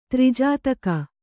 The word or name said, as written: ತ್ರಿಜಾತಕ